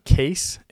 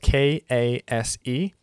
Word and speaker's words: Kase: And specifically, I use uh, Kase. It's spelled K A S E.